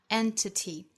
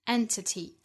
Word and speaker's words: entity: 后面 that 同样起到语法作用，连接后半句，所以做虚词弱读 that any conscious entity can ever hope to do. 这里的 entity 三个音节重读在第一个音节上 entity, entity.